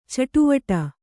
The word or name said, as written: ಚಟುವಟ